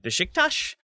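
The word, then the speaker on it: Beşiktaş: The British pronunciation seems to be that like Besiktas or Besiktas. I like there's always the emphasis on that second syllable, and maybe that's how you're supposed to say it. Maybe that's how the Brits say it. But the way I was taught was Besiktas, basically, as like two syllables. You say it very fast, but Besiktas is what I was taught at least.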